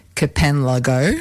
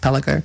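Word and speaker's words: kpanlogo: So, yeah, so it's spelled actually K-P-A-L-A-N-G-O, which you might think sounds like Kapanlago, right? – Yeah, it's like Yalijo down in Canberra. – But being a Ghanaian word in the language of Gar, which is the language it's in, it's pronounced Pelago.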